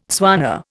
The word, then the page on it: Tswana: Indian